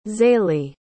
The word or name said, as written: zayleigh